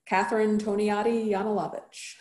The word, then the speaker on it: Catherine Toniatti-Yanulavich: Catherine Toniatti-Yanulavich.